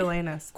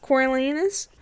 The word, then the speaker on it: Coriolanus: Is it his name Cornelius? Is that how you say it? – Coriolanus. – Coriolanus.